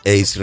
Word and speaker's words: Ace: Es una colección de 26 canciones que ha editado el sello Ace Records, que se escribe A-C-E Records, AC Records, sin H, para aquellos que el inglés no lo tengas. Ace, sí, exacto, Ace, me está indicando aquí Antonio, exacto, Ace, ¿qué es exactamente? – Pues un saque directo que no se devuelve.